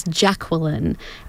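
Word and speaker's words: Jacqualine: My middle name is Jacqueline, and it's spelt with an A instead of an E. So it's J-A-C-Q-U-A-L-I-N-E.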